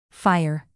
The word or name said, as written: fire